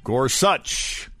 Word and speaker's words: Gorsuch: That's how he pronounces it. Gorsuch.